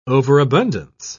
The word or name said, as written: overabundance